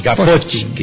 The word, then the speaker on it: Gaposchkin: Bueno, muy bien, después luego tenemos ya, acercándonos al final, Quiero ver cómo la pronunciás esa. Cecilia Payne Gaposchkin. – Astrónoma Cecilia Payne, porque es Gaposchkin. Bueno, ahí está bravo. – Es un apellido ruso, Gaposchkin.